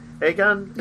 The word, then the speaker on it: Aegon: So his character is really interesting, and he is kind of the future King Aegon? Is that how you say it? – Yeah.